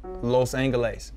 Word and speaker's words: Los Angeles: What's the proper way to say Los Angeles in Spanish? – Los Angeles. – Correct.